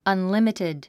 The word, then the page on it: unlimited: ʌnlímitid; アンリミティド